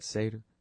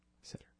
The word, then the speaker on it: Seder: Seder, I guess. Isn't it Seder? Seder.